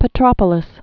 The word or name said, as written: Petropolis